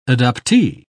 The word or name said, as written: adoptee